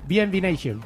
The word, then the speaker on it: VNV Nation: VNV Nation.